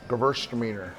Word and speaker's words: Gewürztraminer: Gewürztraminer.